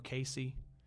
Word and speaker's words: Casey: But anyhow, one Sunday afternoon after church, we went over to that little town that none of y'all pronounce the right way. You know, Casey. People in Terre Haute put a Z in it.